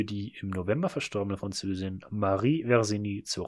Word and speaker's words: Marie Versini: Welche Filmfigur wurde für die im November verstorbene Französin Marie Versini, Moment, die Betonung ist falsch, Marie Versini ist mir scheißegal.